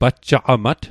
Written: pajjaqamat

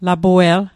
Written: lapue'l

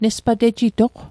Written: nespatejitoq

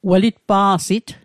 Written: welitpa'sit

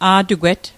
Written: a'tugwet

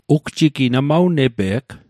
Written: ugjigi

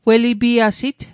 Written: welipiasit

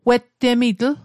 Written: wettemitl

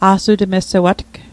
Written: a'sutmessewatg

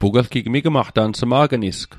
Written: sma'gnis

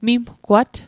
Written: mimgwat

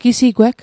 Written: gisigweg

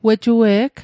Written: wejue'g

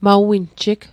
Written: maw winjig

